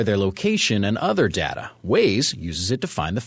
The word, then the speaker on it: Waze: Google is reportedly acquiring the navigation company Waze for more than $1 billion. It's spelled W-A-Z-E.